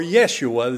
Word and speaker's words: Yeshua: Now, the term salvation, the word salvation, is a word that could also be translated by the Old Testament name of Jesus, which is Joshua, or Yeshua, as some pronounce it in the Hebrew.